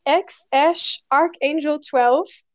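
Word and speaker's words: X Æ A-12: ja, ich, also ich weiß nur, wie sich das schreibt, aber ähm, es spricht sich x Ash Archangel 12.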